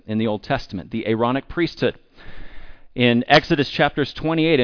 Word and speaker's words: Aaronic: It's not the ironic, it's the Aaronic priesthood, as in the priesthood of Aaron.